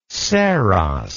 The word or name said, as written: saros